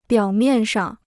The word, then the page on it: 表面上: biǎo miàn shang